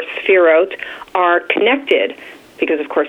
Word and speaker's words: Sephirot: which are called the sephirot. – Say that word again. – Sephirot, S E P H I R O T, Sephirot.